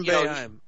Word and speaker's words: Boeheim: Boeheim? How do you pronounce his name over there? – Yeah. – Yeah, Jim Boeheim.